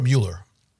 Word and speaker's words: Mueller: Is it Mueller or Mueller? – I like to say Mueller because then it rhymes with Bueller, so you can do the bit from the movie.